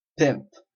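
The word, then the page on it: pimp: Canadian English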